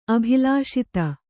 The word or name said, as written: ಅಭಿಲಷಿತ